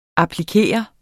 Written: applikere

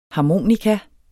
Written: harmonika

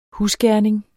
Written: husgerning

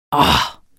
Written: årh

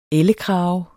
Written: ELLEKRAGER

